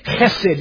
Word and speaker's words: chesed: Chesed. Love that word. H-E-S-E-D. Chesed. You got to spit to be a good Hebrew and say it right.